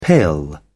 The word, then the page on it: pill: British English